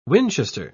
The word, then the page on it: winchester: British English